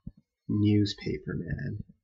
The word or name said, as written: newspaperman